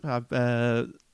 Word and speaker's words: Mar-a-Lago: Mar-a-Lago, yeah.